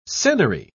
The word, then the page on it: senary: British English